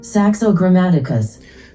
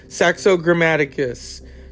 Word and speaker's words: Saxo Grammaticus: Saxo Grammaticus. – Saxo Grammaticus.